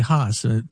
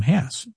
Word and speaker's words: Hess: So it, most people say Haas, but Rudolf Hess actually pronounced his name Hess.